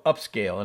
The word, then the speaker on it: Upscayl: So this application is called Upscale, and it's spelled UPSCAYL, right?